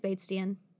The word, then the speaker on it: Batesian: Because his name is Bates. But I think it's spelled like Bastian. No, I'm wrong. It's Batesian.